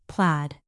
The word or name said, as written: plaid